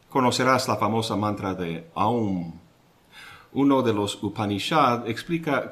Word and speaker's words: Aum: El sonido se inicia en la garganta con la A. A. Luego llena la boca con la U. Au. Y se cierra con la M. Aum.